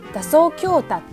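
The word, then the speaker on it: 打草驚蛇: 字を書いて打草強打と読みます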